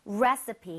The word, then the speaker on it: recipe: 익숙한데 우리가 레시피라고 이제 외래어 표기는 하지만 실제로 발음할 때는 레서피에 가깝게 발음을 좀 했으면 좋겠거든요. 오케이. 자, 여러분들도 같이 한번 해 볼게요. 레시피보다는 레시피. 레시피. 앞쪽에 강세를 주고 서에 가깝게 갑니다. 레시피. 레시피. – Recipe.